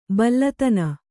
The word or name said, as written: ಬಲ್ಲತನ